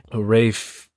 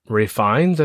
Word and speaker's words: Ralph Fiennes: 2022 horror comedy starring Anya Taylor Joy, Rafe Ray Fiennes, I think is how you say it, uh, but it's spelled Ralph Fiennes, but Ray Fiennes, Nicholas Hoult, and Hong Chao, uh, Janet McTeer, and uh, John Leguizamo's in there.